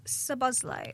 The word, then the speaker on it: Szoboszlai: Well, Dominic Szoboszlai. – Oh, very well pronounced.